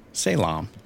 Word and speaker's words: Salem: Yeah. Salem. That's how you say it.